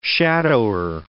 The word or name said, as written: shadower